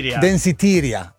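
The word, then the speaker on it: Danceteria: Tra l'altro, a proposito di Madonna e la traccia Danseteria, come la pronuncio io, ma scritto ancora Franciosi, mi fa, guarda che si chiama, com'è? – Danseteria,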